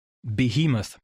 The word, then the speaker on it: Behemoth: Bevor Rainer uns den Grund dafür nennt, möchte ich nur ganz kurz pausieren und sagen, dass er mit, wie hat er es ausgesprochen, Beomorph oder so ähnlich, dass er wahrscheinlich Behemoth meint.